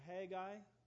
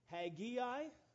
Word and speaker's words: Haggai: There are two ways that are absolutely correct to pronounce his name. You can pronounce it Haggai, so Haggai, or you can pronounce it Haggai. And you know, there's always a wise guy. And so somebody else told me this week that here's another alternate translation that you can pronounce it, Hey Guy. I think we should have, is there a slide there with that? Hey Guy. So whichever way you prefer to pronounce it, they are all correct.